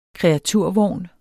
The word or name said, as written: KREATURVOGN